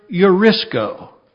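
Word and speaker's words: Eurisko (εὑρίσκω): but it's the Greek word eurisko. Eurisko. Let's say that. Eurisko. Not Eureka, but Eurisko.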